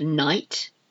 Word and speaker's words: knight: He's my idol, my I-D-O-L, my idol. Then we have night. A night. This is a silent K on the first one. K-N-I-G-H-T. And then N-I-G-H-T.